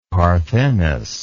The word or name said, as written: parthenos